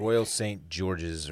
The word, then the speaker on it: Royal St. George's: Royal St. George's, or I guess that's how they say it, right? – Yes.